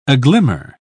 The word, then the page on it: aglimmer: British English